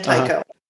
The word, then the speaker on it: Tycho: Almost. Paula, as in Paula, you know, and then Tycho.